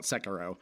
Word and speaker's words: Sekiro: Yeah, I got all the way to the last boss and you're calling it Sakura. I'm going to call it Sekiro.